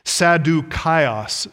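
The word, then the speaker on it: Sadukaios (Σαδδουκαῖος): The Greek for Sadducees is actually pronounced Sadukaios, Sadukaios.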